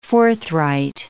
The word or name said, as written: forthright